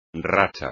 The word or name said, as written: racha